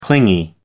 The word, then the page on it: clingy: American English